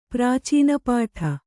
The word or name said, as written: ಪ್ರಾಚೀನಪಾಠ